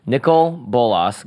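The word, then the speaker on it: Nicol Bolas: Nissel Bolas. God Farah. Nickel Bolas, God Pharaoh.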